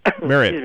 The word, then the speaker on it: Marriott: So Steve Marriott, how do you say it? Marriott or Marriott? – Marriott.